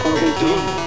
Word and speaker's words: Perdón: He wants to begin a conversation, so he says, Perdón. This, of course, is not the English word pardon, but the pronunciation is quite different.